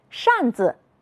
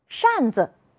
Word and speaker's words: 扇子: 扇子，是的，耷拉，竖着。你发现了什么？有的同学说，老师，后一个字都要读成轻声。你们真会观察，我们再来读一读。扇子，是的，耷拉，竖着。